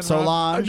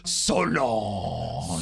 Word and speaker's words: Solange: When Beyonce's sister Solange, how do you pronounce those names again? – Solange. – No, that's not how you pronounce it. The more famous sister is Beyonce, and then her little sister is Solange.